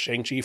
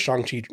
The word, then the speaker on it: Shang-Chi: Shang-Chi is the correct pronunciation. I've been saying it Shang-Chi forever. – Same here. – So like, sorry. Crap, I've been saying that wrong for the past 30 some years or whatever.